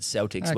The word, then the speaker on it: Celtics: Pronounce it Celtics when you're in American territory.